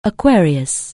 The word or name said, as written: Aquarius